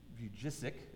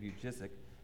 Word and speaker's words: Vujicic: There's a guy, you may have heard of him, Nick, I believe it's pronounced Vujicic is his name, Nick Vujicic.